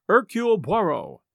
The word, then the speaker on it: Hercule Poirot: his name being Hercule Poirot. And yes, I checked, and that's how his name is usually pronounced.